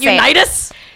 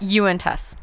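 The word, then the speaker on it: Uinta: I was like, Unitas? – Yeah. It looks like Johnny Unitas, but it's not. – It's Uintus? – Uintus. – Uintus? – Yes. – Yes. – Like Uintus like ghosts?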